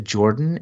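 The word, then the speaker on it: Jordon: Yeah, anyone wanting to reach out to me, just send it to Jordan. And my name is spelled kind of funny. It's J-O-R-D-O-N.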